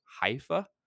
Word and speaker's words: Haifa: Haifa.